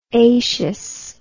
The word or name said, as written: aceous